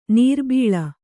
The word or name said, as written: ನೀರ್ಬೀಳು